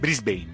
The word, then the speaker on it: Brisbane: Brisbane si legge? – Brisbane. – Perché scrive come Brisbane? – Perché io te l'ho scritto come è scritto.